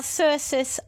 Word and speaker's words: Cercis: Circis, Oklahoma. – Circis, how do you spell that? – C-E-R-C-I-S.